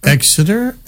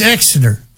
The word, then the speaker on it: Exeter: Actually, we were in Exeter, and this guy said, I'm saying it wrong. And I go, Exeter? And he goes, no, it's Exeter.